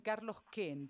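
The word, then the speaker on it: Carlos Keen: Y vive en Carlos Ken. Pese a que tiene dos E, se pronuncia Ken.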